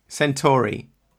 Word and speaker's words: Centauri: So what is the I have to ask, actually, my first question is, how do you pronounce it? The Centauri? – Centauri. – Oh, Centauri. – There's probably not a right answer. I call it Centauri, as in Alpha Centauri.